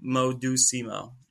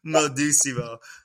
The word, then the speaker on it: Modusimo: So, like, you just, you know, say it as the way it's spelled out. It's called Modusimo, you know. Modusimo.